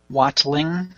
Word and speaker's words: Watling: Watling.